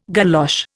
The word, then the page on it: galosh: Indian